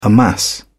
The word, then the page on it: amass: British English